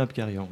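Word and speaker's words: Abkarian: L'ai-je bien prononcé ? – C'est bien Apkarian, oui.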